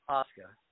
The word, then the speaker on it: Asuka: Asuka.